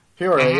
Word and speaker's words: Peoria: Peoria? – Peoria, yep.